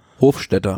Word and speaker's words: Hofstetter: Und ja, Gaviria, wie du schon eben sagtest, glaube ich, vor Peter Sagan, für Alvaro José Hodegh, Belletti, Hofstetter, nee, wie spricht man aus? Hofstetter? – Hofstetter.